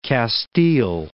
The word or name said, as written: Castile